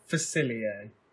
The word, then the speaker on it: Facilier: I do like Dr. Facefiller. I can't pronounce his name. – Facilier. – Facefiller. – Facilier. – Shadow Man. – At least try. Facilier.